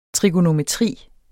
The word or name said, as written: trigonometri